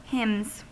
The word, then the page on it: hymns: American English